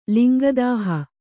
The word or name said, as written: ಲಿಂಗದಾಹ